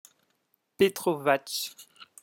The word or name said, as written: petrovach